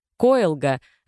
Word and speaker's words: Коэлга: Коэлга — обратим внимание на то, что ударение в этом слове на «о» в первом слоге — это белый мрамор, добываемый в месторождении рядом с казачьим селом Коэлга, тоже в Челябинской области.